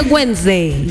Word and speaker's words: Wednesday: Wednesday se lee. Wednesday. Aquello de Cardosa le estoy leyendo como escribe. Pero se pronuncia Wednesday.